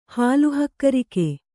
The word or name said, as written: ಹಾಲುಹಕ್ಕರಿಕೆ